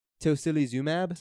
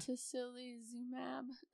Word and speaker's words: tocilizumab: Tocilizumab. Is that how you would say that? Tocilizumab. – Tocilizumab.